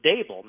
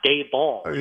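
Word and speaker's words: Daboll: And I think Coach Brian Dable is Dable, not Dayball.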